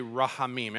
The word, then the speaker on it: rahamim: The K is silent, so you just say rahamim.